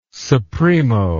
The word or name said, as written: supremo